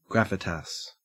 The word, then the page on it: gravitas: Australian English